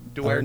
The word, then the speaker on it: Duterte: Duterte.